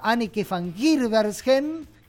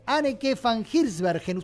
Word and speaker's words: Anneke van Giersbergen: Hoy, por ejemplo, toca Anneke van Giersbergen, como me dijo alguien que habría que pronunciarlo. Anneke van Giersbergen, ustedes saben de quién estoy hablando, Agua de Anneke, The Gathering, su ruta.